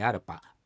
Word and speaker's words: arpa: 発音としましてはエアルパ、アルパっていうのはどこかに行くなんですけど、小さいラで書いてますね。